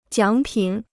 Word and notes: 奖品: jiǎng pǐn

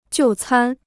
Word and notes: 就餐: jiù cān

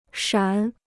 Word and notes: 闪: shǎn

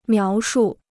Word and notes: 描述: miáo shù

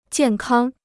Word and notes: 健康: jiàn kāng

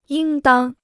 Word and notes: 应当: yīng dāng

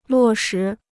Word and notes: 落实: luò shí

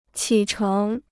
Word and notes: 起程: qǐ chéng

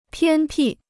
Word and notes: 偏僻: piān pì

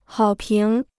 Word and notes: 好评: hǎo píng

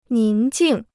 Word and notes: 宁静: níng jìng

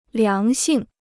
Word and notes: 良性: liáng xìng

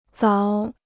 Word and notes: 凿: záo

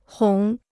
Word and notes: 哄: hòng

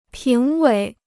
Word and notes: 评委: píng wěi

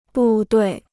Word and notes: 部队: bù duì

